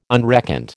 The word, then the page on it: unreckoned: American English